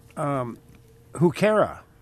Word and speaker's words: Heuchera: Heuchera, which is, I believe it's spelled H-E-U-C-H-E-R-A.